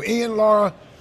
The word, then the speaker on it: Ian Lara: Joe will welcome, I keep getting his name wrong, Ian Lara? – Ian. – There you go, Ian Lara.